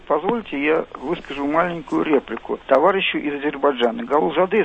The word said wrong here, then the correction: Гулузаде: если я не ошибаюсь, да? Правильно сказал? – Да, немножко вы говорили именно с московским, я бы так сказал, акцентом. Вафа Мирзоевич Гулузаде, правильно? – Ну, ради бога, извините, пожалуйста, не хотел обидеть.